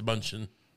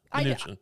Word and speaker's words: Mnuchin: I believe it's Mnuchin. – Mm. – You didn't know how to say it. Mnuchin? Mnuchin is it. Mnuchin.